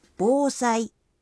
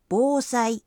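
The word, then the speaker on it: বোসাই (防災): এই কাঞ্জির উচ্চারণ হল বোসাই, বোসাই।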